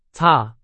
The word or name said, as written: 擦